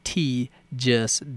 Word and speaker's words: Tee-jus: That's Life Gets, G-I-T-S. Folks, write this down. This is an important phrase that you all need to use in your daily life. T-E-E-J-U-S, comma, Don't It. Life Gets T, Just Don't It.